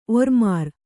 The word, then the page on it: ಒರ್ಮಾರ್: orār